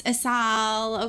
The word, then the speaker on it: Asal: They were like, oh, it's pronounced SL. And, like, he was just, like, saying it, like, weird. And I was like, okay, no, it's Asal, okay. But, like, I'm also, the way that I say my name is, like, the, like, white version.